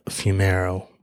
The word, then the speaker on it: Fumero: It is Melissa Fumero, F-U-M-E-R-O.